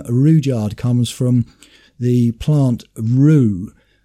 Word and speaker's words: Rudyard: I think that other names that begin with R, U and D, such as Rudy and Rudolph, they're names that have that R, U, Ru pronunciation rather than the hard U, because nobody would call somebody who's called Rudolph Rudolph, and nobody would call somebody called Rudy Ruddy. And so it does make sense that Rudyard should be pronounced that way.